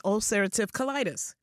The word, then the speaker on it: ulcerative colitis: Now, we're going to talk today about inflammatory bowel disease and Crohn's and ulcerative colitis.